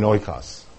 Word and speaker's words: oikos: It's spelled in English as O-I-K-O-S, oikos.